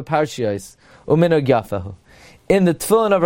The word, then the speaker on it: Tefillin: Tefillin, the lamid has a dagesh. Some would say the way to pronounce it, therefore, is it's as if there are two lamids. Tefillin. Tefillin. Okay, so most accurately, lohaniach tefillin. It's like there's a double lamid.